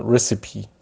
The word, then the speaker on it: recipe: recipe تلفظش یه ذره با دیکتش فرق داره، یعنی پیش خون یه جور دیگه تقریبا خونده میشه.